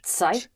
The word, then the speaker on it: Zeit: Ich habe keine Zeit. – Zeit. – Zeit. – The Z or the Z, yes. It's spelled Z or Z, E-I-T. And that is pronounced like a T-S, Zeit.